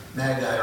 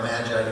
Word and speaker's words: magi: Now, I don't know about you, but we didn't use the word magi or magi, depending on whether you're from New Jersey or not, how you pronounce it.